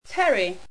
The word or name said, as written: pteri